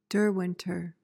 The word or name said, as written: derwenter